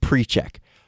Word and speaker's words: Pericek: And this is the guy, the offensive lineman, whose name is spelled P-E-R-I-C-E-K. And so in preparation for this podcast, I looked up how to actually pronounce it, because I've said Parasek or Parachek in the past. It's pronounced Preczek.